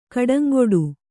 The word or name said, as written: ಗಿಡುಂಗಡಿ